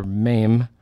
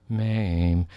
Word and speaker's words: Mem: If you want the Hebrew letter that heads this section, it's the letter Mem, as in you coax the blues right out of the horn. Mem. Only it's spelled M-E-M. See, now you would never expect a guy that looks like me to know show tunes, would you? Mem, M-E-M.